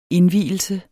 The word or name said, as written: indvielse